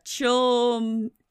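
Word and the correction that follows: Chlumsky: Is that how you say it? – Anna Klumsky.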